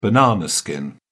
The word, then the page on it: banana skin: British English